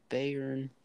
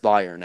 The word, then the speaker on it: Bayern: Bayern Munich. It's pronounced Bayern, actually, but yeah.